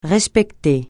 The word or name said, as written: respecter